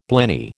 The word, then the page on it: blenny: American English